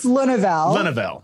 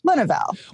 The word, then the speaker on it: Lenneville: Okay, so Americans can't say my name. It's a thing. You guys took Spanish instead of French. – Yes. – So it's Lenneville. But okay. Lenneville.